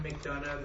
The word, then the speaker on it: McDonough: My name is John McDonough. That's spelled M-C, capital D-O-N-O-U-G-H.